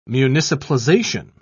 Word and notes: municipalization: British English

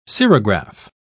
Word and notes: cerograph: British English

